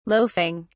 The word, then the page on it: loafing: British English